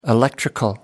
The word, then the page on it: electrical: American English